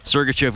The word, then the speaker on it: Sergachev: And what is, it's, how do you say, Sergachev? – Sergachev, who went to Tampa Bay.